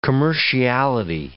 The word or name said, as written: commerciality